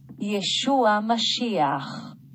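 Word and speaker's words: Yeshua HaMashiach: 然后所以这个弥赛亚呢，耶稣基督，如果是从这个犹太人的发音哈，是这样哈，再给大家听一次。 – Yeshua Mashiach， Yeshua Mashiach， Yeshua Mashiach。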